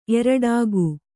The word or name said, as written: ಏರಾಟಿಕೆ